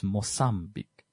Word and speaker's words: Mozambique: That's why Mozambique is not Mozam with a Z in English. It's Mozambique. That's how the Portuguese pronounce it.